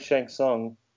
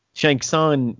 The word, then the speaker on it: Shang Tsung: No, he played Shang Tsung. – Shang Tsung. Oh, yes. Shang Tsung.